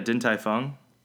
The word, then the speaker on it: Din Tai Fung: Did I say it correctly? – Yeah, Din Tai Fung.